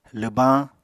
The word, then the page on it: lwbañ: Apatani